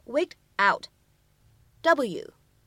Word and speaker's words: wigged out: out，它呢其实会有一个对应的形容词表达，叫做 waked out。W